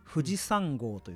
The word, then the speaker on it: 富士山号: 富士山号といって 223 号と書いて富士山と読む